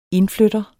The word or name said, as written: indflytter